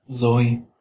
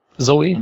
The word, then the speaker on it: Zoe: Shui Saldana, spricht man das so aus? Zoe. Zoe, mhm.